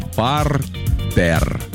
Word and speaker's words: партер: Есть такое слово «партер». Слышали наверняка. – Да. – Так, как же все-таки правильно говорить? «Партер» или «партер»?